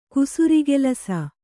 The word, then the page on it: ಕುಸುರುಕೆಲಸ: Kannada